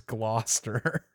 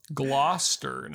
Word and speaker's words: Gloucester: So, yeah, Logan says, first off, you got to learn how to say Gloucester. Nolan said it right at one point. It's Gloucester. So, Gloucester.